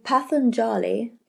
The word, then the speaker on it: Patanjali: So Patanjali, am I saying that right? – You are.